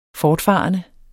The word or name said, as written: fortfarende